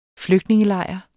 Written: flygtningelejr

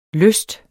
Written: lysten

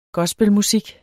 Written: gospelmusik

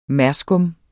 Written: merskum

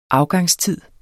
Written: afgangstid

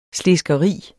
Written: SLESKERI

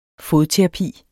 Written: fodterapi

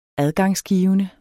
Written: adgangsgivende

